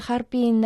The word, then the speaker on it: 哈尔滨: Название города Харпин на китайском языке звучит почти так же, как на русском. Оно состоит из трех иероглифов «Харпин». Первый иероглиф «ха» произносится в первом тоне.